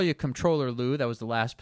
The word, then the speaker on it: comptroller: Yes, and you're actually pronouncing it right. – Ah, yes. I try to, like, combine the M and the N, comptroller, you know, and just skate by.